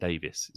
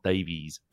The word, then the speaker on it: Davies: Everybody in Wales calls it Davis. It's not Davies. Davies isn't a name in Wales. It's Davis.